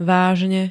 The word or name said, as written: vážne